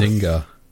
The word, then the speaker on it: Zynga: We then had a surprising announcement in the form of Star Wars Hunters, a mobile game from, how do you pronounce their name? Zynga? – Zynga. Yeah.